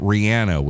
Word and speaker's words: Rihanna: There is Rihanna. And yes, you're supposed to say it Rihanna, which bugs me because I'm an aunt, not an aunt person. But that's how she pronounces it.